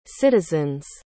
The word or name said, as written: citizens